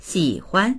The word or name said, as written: xi3huan